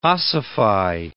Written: ossify

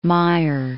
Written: Meyer

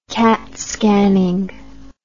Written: CAT scanning

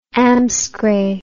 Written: amscray

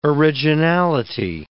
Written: originality

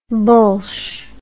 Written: bullsh

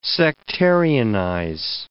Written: sectarianize

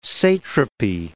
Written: satrapy

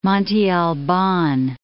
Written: Monte Alban

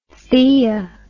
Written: theia

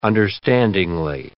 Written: understandingly

